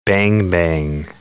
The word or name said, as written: bang-bang